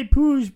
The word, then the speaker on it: Puig: He it's pronounced Ricky Pouge, but most people I think I'd not have known it was spelled P U I G. – Probably people are going to pronounce it Poog or Puig.